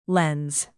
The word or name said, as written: lens